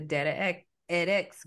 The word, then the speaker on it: DataEdX: So I'm always messing that word up. I want you to say it the way you pronounce it. So the company that you started is Data edX group, and it is data education with the X factor.